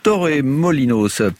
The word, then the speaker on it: Torremolinos: Bon, Torremolinos, c'est à prononcer à la Dali, donc on en parle avec Charline Roux, Carina Rossa, dans deux minutes, juste après le morceau.